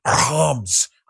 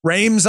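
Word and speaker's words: Reims: Actually in the Champagne region in Homs. I can't say it like the French say it. We went up to the Champagne region. Homs. They do this throat thing. It's Rames, I guess, in English.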